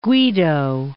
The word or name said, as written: Guido of Arezzo